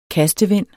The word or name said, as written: kastevind